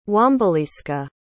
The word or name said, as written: wambleeska